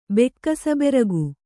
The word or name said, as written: ಬೆಕ್ಕಸಬೆರಗು